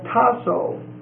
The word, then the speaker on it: tasso: Comes from A, which is the negative, and tasso, T-A-S-S-O, the long O, T-A-S-S-O.